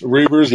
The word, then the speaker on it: Reuvers: And you have Potter and Reuvers, or however you say his name. – Reuvers, yeah. Reuvers, yes.